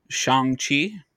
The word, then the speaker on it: Shang-Chi: but this is Shang-Chi. – You got it. – Okay. Yeah. Marvel's new movie. – Shang-Chi.